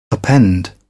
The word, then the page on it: append: British English